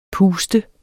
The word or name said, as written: puster